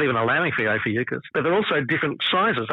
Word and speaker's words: Ophiuchus: Someone just announced that it was there, which I've always pronounced it Ophiuchus. Others are saying Ophiuchus. But yeah, Ophiuchus, which is... – Yeah, Ophiuchus is how I've always pronounced it. – Yeah. I've been a hard C.